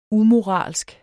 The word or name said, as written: umoralsk